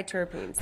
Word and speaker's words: triterpenes: I don't know how to pronounce it, but it's triterpenes? No. – Yeah. Triterpenes or something? – Whatever. Yeah, triterpenes.